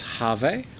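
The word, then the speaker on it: Hervé: I think it's pronounced Harve. – Oh, Herve. If he's Harve. French, Herve. They don't say H.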